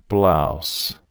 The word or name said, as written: blouse